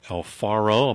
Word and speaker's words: El Faro: It was called the El Faro. Am I pronouncing that correctly? – That's correct. And El Faro in Spanish actually means lighthouse.